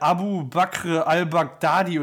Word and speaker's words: Abu Bakr al-Baghdadi: Unter anderem habe ich gelesen hier der, der Anführer des Islamischen Staates, der Abu Bakr al-Baghdadi oder wie der heißt. Äh, Freddy, habe ich das ri einigermaßen richtig ausgesprochen? Ich müsste es lesen, damit ich es weiß, aber es hört sich richtig an.